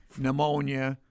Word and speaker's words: pneumonia: Ptomaine, a silent P, pneumonia.